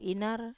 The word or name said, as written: inar